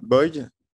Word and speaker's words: Boie: Et aujourd'hui, euh, je reçois euh, Gideon Boy ou Gideon Bois, selon, le, selon la prononciation que vous voudrez.